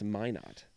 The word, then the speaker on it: Minot: Was Minot, which I think you told me is pronounced Minot. – Minot. Minot. Minot. Yeah. So I'm giving too much emphasis on the ot.